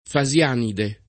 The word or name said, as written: fasianide